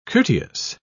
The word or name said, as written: courteous